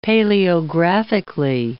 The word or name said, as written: paleographically